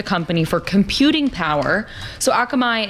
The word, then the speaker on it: Akamai: Akamai, am I saying it right? – Yeah. Akamai. Mm-hmm.